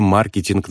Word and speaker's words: маркетинг: Я рекомендую произносить это слово с ударением на первом слоге. Маркетинг.